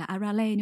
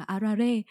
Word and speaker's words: Arare: Thì cả thế giới đều biết đến cái nhân vật này là Arale, nhưng mà thật ra là trong tiếng Nhật nó đọc là Arare.